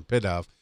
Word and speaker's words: Pidove: I had to say pigeon to get the right pronunciation, Pidove.